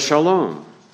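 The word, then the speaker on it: shalom (שלום): Well, the Hebrew word shalom. The Hebrew pronunciation would be shalom.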